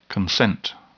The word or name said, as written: consent